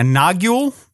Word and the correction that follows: nagual: man? – Nawal. Yeah, Nawal.